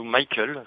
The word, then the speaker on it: Mycle: Alors il y a une grande conversation avec un monsieur qui s'appelle Michael Schneider, je ne sais pas si je le dis bien. – Oui, Michael. – Ouais, voilà, Michael Schneider, comme ça s'écrit M-Y-C-L-E, qui est un spécialiste hein, de qui a bossé dans tous les pays avec beaucoup de monde en conseil sur.